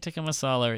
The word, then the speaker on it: tikka masala: I didn't have chicken tikka masala. Is that how you pronounce it? It's probably wrong. I'm sorry. – No. It's chicken tikka masala. It's a British-made curry.